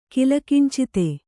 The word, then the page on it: ಕಿಲಿಕಿಂಚಿತ: kilikincita